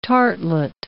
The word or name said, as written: tartlet